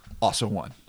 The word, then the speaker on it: Ausso: Because it is A-U-S-S-O. – So, Ozo? – O-N-E. – Okay. – Also one.